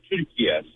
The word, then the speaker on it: Türkiye: The way Turkish people pronounce it is actually the way it's said, Türkiye.